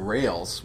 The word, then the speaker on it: rales: So this word rails, which for our dutiful listeners is spelled R-A-L-E-S, right? Did I get that right? – That is correct.